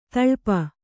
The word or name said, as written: ತೊಳಪು